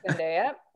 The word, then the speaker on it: Zendaya: So you don't buy this Zendaya, am I pronouncing her name right? – Zendaya.